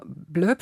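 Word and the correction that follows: Bloéb: Spricht, Bleib, Bleib. Sp spricht man es aus. Also Bleib. O und dann E, Accent aigu genau. und dann genau. nochmal ein B.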